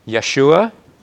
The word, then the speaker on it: Yeshua: And a more Hebrew pronunciation of Joshua would be Yeshua, because they don't have the J sound in Hebrew, and so it's an anglicized thing when we put a J sound on any Hebrew word.